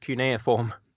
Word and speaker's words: cuneiform: Daniel recognises it as a cumiform, one of the earliest writings known to mankind, and he's actually able to translate it. – Yeah, also it's actually pronounced cuneiform.